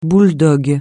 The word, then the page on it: bouledogue: francais